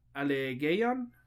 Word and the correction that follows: Allegiant: Allegiant. – Allegiant. God, I fucking butchered that horribly.